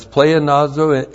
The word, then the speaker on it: pleonazo: And then the hina plus the subjunctive of pleonazo. P-L-E-O-N-A-Z, long O. Pleonazo.